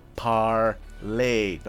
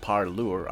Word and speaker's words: Parler: In this instance, it's parlay, pronounced parlay, not parlure.